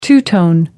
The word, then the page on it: two tone: American English